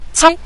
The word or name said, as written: cai5